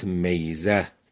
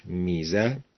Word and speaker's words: ميزة: گاهی اوقات میزه تلفظ می شود، ولی تلفظ درستش میزه به کسر میم است، یعنی ما یمتاز به من صفت او خاصه.